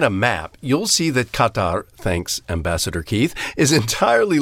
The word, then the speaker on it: Qatar: Well, Murray, the way to pronounce it in classical Arabic is Qatar, but not many of us speak classical Arabic. So if you put the emphasis on the first syllable, whether it's Qatar or Qatar or Qatar, you can't go very wrong. If you put the emphasis on the second syllable, you're marked immediately for a stranger.